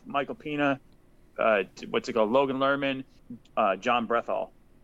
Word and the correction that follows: Bernthal: the Punisher. I apologize if I said his name wrong. Bernthal. It's about what's that? – Bernthal. – Yeah, Bernthal. Sorry.